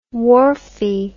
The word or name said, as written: wharfie